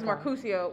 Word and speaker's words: Mercutio: First of all, he's Mercutio.